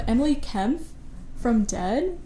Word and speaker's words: Dehd: Dead, it's spelled D-E-H-D, and they're from Chicago.